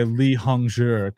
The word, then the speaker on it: Li Hongzhi: Li Hongzhi, is that how you say his name? – Yeah, I believe so. I mean, I try and say Li Hongzhi because I found, you know, I try to watch the videos and figure out the pronunciation.